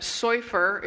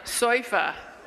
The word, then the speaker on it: Soifer: but Soifer is like surfer with a Brooklyn accent. Okay? So can you say that? Soifer.